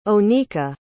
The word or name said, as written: onika